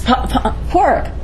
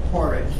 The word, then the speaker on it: Padraic: Porrick. – Porrick. The spelling is not the same. – I know, it's just like The D is not pronounced in the Western dialect.